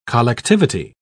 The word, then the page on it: collectivity: British English